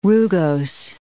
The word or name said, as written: rugose